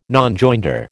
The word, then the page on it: Nonjoinder: American English